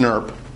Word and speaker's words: snRNP: That's what SNRNP stands for, in other words, which is sometimes pronounced SNRP.